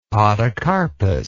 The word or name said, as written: podocarpus